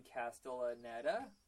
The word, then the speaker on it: Castellaneta: Dan Castellaneta.